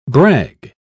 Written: brag

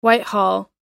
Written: whitehall

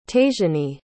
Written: tashiany

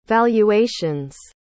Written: valuations

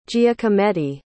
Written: giacometti

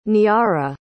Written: niara

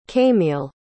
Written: caimile

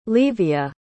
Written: levia

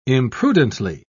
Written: imprudently

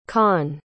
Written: con

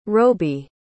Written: roby

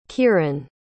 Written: kiran

